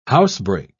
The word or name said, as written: housebreak